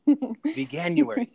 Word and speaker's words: Veganuary: And remember that this is Veganuary, right? Did I say that right? Veganuary month? – Yeah, Veganuary. – Veganuary. Thank you. I had the right wording and not the right pronunciation.